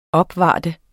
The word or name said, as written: opvarter